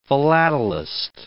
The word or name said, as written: philatelist